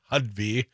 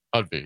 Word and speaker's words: Hovde: Well, when you talk about the best and brightest from Wisconsin, I'm less clear that either Eric Hudby, and I've learned, I heard his ad last night, it's Hudby. – Hudby, yes. – I was calling him Hudby, but, emphasis on the ho, like sellout, Laguna Beach sellout ho.